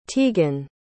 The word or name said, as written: teagan